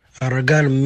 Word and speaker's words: Aragan: ARAGAN means sweat, like your yeah, blessing. So it's actually pronounced ARAGAN. ARAGAN means sweat, like your sweat.